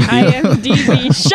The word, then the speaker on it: IMDb: What would we do without IMBD? – IMBD. We would not live. Yeah, international movie. Database? Base of data is IMBD. – IMDB.